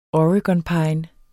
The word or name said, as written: oregonpine